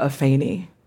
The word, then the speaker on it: Afeni: My name is Afrika Afeni.